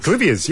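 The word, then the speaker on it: Clivia: We've got from Killabin Bay, and she's got a question about Clivias. – Clivias, yes. Yeah, is that how you say it? – It is how you say it.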